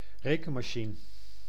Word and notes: rekenmachine: /ɾe.kən.ma.ʃi.nə/

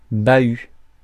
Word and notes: chest: American English; /t͡ʃɛst/

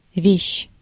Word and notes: вещь: /vʲeɕː/

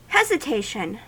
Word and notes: hesitation: /hɛzɪˈteɪʃən/

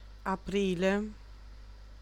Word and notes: aprile: /aˈpriː.le/

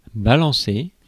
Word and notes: balancer: France; [ba.lɑ̃.se]

